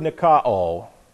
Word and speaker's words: nikao: The word overcome is the Greek word nikao, properly, excellently, flawlessly translated. Nikao, N-I-K-A-O, nikao. This is N-I-K-A-O, long O on the end.